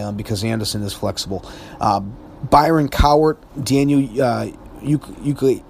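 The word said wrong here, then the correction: Ekuale: I can't even pronounce his name. Ekwali, there it is, Ekwali.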